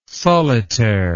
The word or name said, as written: solitaire